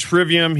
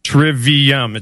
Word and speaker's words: trivium: As he, what he does is he pronounces it how it would be pronounced in Latin. Well, we're not speaking about it in Latin. We're pronouncing it how we would talk about it in English, and it's called trivium.